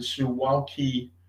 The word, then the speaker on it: Suwalki: And this is called, I think, the Suwalki Gap. I might be mispronounced. – No, I think that's how you pronounce it.